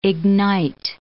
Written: ignite

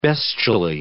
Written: bestially

